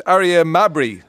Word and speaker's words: Aria Mabry: And also on the line is student Aria Mabry.